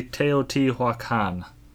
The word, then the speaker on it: Teotihuacan: That is Teotihuacan, or if you want to say it correctly, Teotihuacan. Hopefully that is close enough.